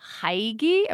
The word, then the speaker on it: hygge: As far as the other word, H-Y-G-G-E, It is a Danish word. It's impossible to pronounce, especially for someone from North Carolina like me. There's a lot of mouth movements in it. So it's kind of like hygge or something similar.